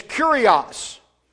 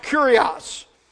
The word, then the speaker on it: kurios: Now, the Greek word for Lord, if you've ever looked it up in Strong's or some other biblical dictionary, the Greek word for Lord is kurios. That's how it's pronounced, kurios.